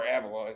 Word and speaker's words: Avila: Like, you know, the issue I have with Al Avila is, or Avila, as he would say. He likes to pronounce Avila. – Oh, excuse me. You have to get the right emphasis on the right syllable.